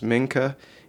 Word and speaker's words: Mincha: There's different pronunciations. Mincha, M-I-N-C-H-A-H is how mine spells it.